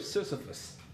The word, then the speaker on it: Sisyphus: The other one I want to talk about is the story of Sisyphus. It's actually spelled phonetically S-I-S-Y-P-H-U-S.